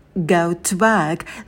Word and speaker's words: go to work: И в слове «т» не нужно произносить как «ту», да, такая сильная форма. У нас будет звук «шва» такой расслабленный, и мы сильно предлоги не выделяем. Go to work.